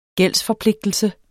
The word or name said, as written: gældsforpligtelse